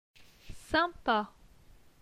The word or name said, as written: Sympa